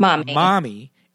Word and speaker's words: Marmee: So Alcott's New Englander guess could have been that the spelling was M-A-R-M-E-E. Although the movie's actors pronounce it Marmee, it should probably be pronounced mommy.